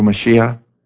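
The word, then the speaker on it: Mashiach (מָשִׁיחַ): or pronounced in Hebrew Mashiach, is the anointed one, the one that was to come to save their people from their sins, Jesus.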